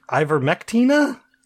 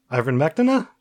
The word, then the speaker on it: ivermectin: But there's an A on the end. – No. – Ivermectina? Yeah, there's an A on the end. – No. – A silent A. – Invisible A. – It's right on the box. – Oh, well, that's the brand name. That's not the drug.